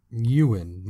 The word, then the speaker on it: Ewan: Ewan.